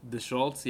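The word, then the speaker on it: Deschutz: The ACG, how do you pronounce that? Deschultz? – Deschultz, yeah, Deschultz.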